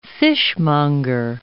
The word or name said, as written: fishmonger